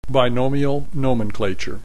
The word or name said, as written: binomial nomenclature